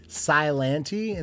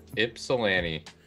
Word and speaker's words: Ypsilanti: It's like, I looked it up the first time because I think I tried to like make the Y silent. I was like, how do you say that city name? Is it like Silanti? And these guys laughed at me for like 30 minutes. And what is it? Ypsilanti?